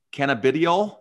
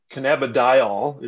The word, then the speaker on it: cannabidiol: And of course, we're talking about sports betting and cannabidiol. Did I say that right? – Well, kind of. It's a cannabidiol, if you're talking about CBD. Wow. – That wasn't close.